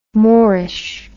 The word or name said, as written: morish